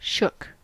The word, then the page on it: shook: American English